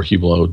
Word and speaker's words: Hublot: They own a lot of different brands, including Hublot or Hublot, depending on how you want to pronounce that, Tag, and Zenith.